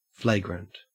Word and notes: flagrant: Australian English

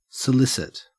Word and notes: solicit: Australian English

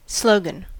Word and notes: slogan: American English

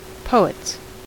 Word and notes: poets: American English

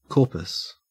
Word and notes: corpus: Australian English

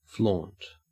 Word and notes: flaunt: Australian English

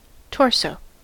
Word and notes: torso: American English

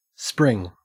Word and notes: spring: Australian English

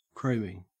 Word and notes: chroming: Australian English

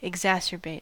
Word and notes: exacerbate: American English

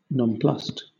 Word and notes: nonplussed: British English

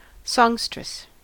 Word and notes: songstress: American English

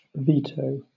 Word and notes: veto: British English

